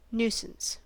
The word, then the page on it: nuisance: American English